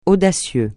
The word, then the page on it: audacieux: francais